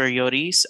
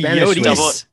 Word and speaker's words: Lloris: You're like saying Lloris. – Yeah. – Lloris? – It's the Spanish way. I'm sorry. – He's French. – Double L's in Spanish and French and Latin is pronounced almost like a Y.